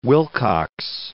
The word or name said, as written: Willcocks